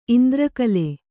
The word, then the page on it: ಇಂದ್ರಕಲೆ: Kannada